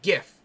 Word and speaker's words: Giff: I believe it's pronounced GIF. – No, it's GIF. G-I-F-F. GIF.